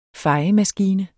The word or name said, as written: fejemaskine